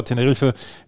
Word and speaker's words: Tenerife: Teneriffa, Tenerife, wie es dann im Spanischen ausgesprochen wird.